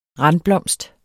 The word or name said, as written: randblomst